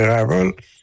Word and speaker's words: Rival: On va le prononcer, c'est une marque de commerce en anglais, donc chez Rival.